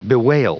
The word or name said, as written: bewail